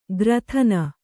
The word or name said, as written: ಗ್ರಥನ